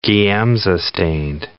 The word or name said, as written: Giemsa-stained